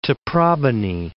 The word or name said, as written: Taprobane